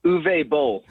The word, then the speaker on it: Uwe Boll: He's in a bunch of those Ool Bowl, if I'm saying it correctly, horrible Ool Bowl movies. – How do you say that German director's name? – Uwe Boll.